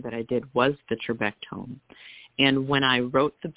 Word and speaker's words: trabectome: But I wanted to mention The Building Blocks, and I don't know if I'm pronouncing this right, trabectome surgery. Is that how you say it? – Yeah, that's correct.